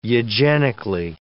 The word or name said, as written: eugenically